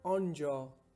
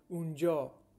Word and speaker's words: anja: To say there in Persian, we say Anja, or you can say Unja.